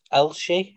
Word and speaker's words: Elche: Elche, I believe it's pronounced. – Yep.